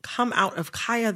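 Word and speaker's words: Khia: I am going to, in this conversation, pronounce Kaya, the rapper, with the long I. Kaya. Okay, my neck, my back, mm-hmm. for those who need a refresher. Kaya, okay? Mm-hmm. The Kia Asylum is a mispronunciation of her name.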